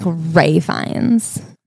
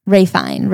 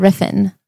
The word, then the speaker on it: Ralph Fiennes: I think it's like Ray Fiennes. Ray Fiennes. Riffin. Riffin.